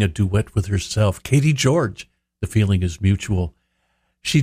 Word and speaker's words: Gyorgy: And her name is spelled a little differently, G-Y-O-R-G-Y, but it's pronounced George.